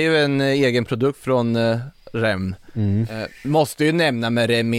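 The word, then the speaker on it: Reims: Men han den unga killen från, vad säger man, Rem, det är så man uttalar det, va? – Ja.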